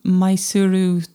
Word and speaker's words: Mysuru: Yeah, so it's Mysore in English, or Mysuru, they would spell and pronounce it.